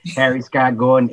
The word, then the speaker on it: Harry Scott Gordon: Harry Scott Gordon, H.